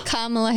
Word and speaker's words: Kamala: And or say February, March of 2023, Kamala? Whatever the hell her name is. – Kamala Harris. – Are you serious? Kamala. I've been saying that wrong for that long.